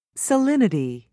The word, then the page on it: salinity: British English